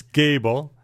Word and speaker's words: Gable: Everybody calls it Gable. It's Gable. The A has a little line over it, so it's a long A.